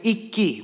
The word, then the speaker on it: Ikki: My name is Ikki. That's how most people say it. But in Japanese, my real name is Ikki. That's how you pronounce it. But I just say Ikki because people don't want to say Ikki.